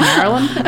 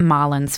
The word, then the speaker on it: Marilyn: Excuse me, I think it's pronounced Marilyn. – Sorry. I think you'll find that it's actually Marilyn. So it sounds like Marlon's family were rich because her dad just casually bought 3 KFC stores for Gacy to manage.